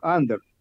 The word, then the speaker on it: Андор: Ударение на первый слог. Андер.